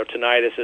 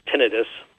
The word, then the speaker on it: tinnitus: You know, tinnitus is or some people pronounce it as tinnitus, but I guess those of us in the South refer to it as tinnitus.